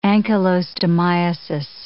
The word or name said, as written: ankylostomiasis